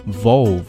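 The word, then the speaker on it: Volvo: 好，那么下面我们就来看一下 Volve 注意沃尔沃这个词啊，读作 Volve 啊，中间 O 还有个 L。 Volve has now designed 的，连起来 designed complete 尾音的 t 也省略了，为了方便 complete system solution Volve has now designed a complete system solution.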